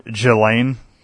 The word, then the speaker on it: Ghislaine: Now, they say it's pronounced Ghislaine, but I call her Jizz Factory.